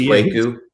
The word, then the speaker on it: Kwaku: With a long A. There's a it's Kwaku. – Who is this guy? – Some people have mispronounced that, but yeah. The correct pronunciation, I believe, is Kwaku. His last name is L-E-L, Kwaku L.